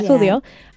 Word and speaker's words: Sudio: S-U-D-I-O. Sweden. – Studio. – Studio. We just like to imagine that that's how they say it. Yeah. Studio.